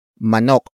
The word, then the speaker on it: manok: And that is manok. Manok. Manok. Manok is spelled as M-A-N-O-K.